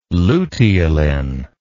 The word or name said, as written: luteolin